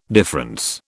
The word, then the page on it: difference: British English